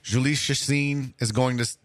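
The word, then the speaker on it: Chacín: I can never say his name right. – Chacin, yep.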